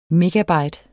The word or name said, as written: megabyte